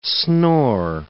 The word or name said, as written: snore